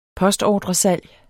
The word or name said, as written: postordresalg